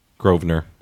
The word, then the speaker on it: Grosvenor: Grosvenor. – Yeah, the S is silent? – Yep.